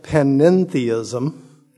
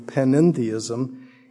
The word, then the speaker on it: panentheism: One is called panentheism. No, I did not mispronounce pantheism. This is a separate worldview, panentheism.